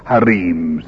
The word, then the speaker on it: harems: harems, or harems, if you want to pronounce it the Eastern way.